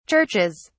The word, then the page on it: churches: British English